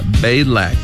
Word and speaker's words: Balak: 자, 먼저 발락을 영어로는 어떻게 발음할까요? 잘 듣고 따라해 보시기 바랍니다. Balak, Balak, B-A-L-A-K, Balak. 이렇게 연습해 주시고 십볼은 Z로 시작합니다.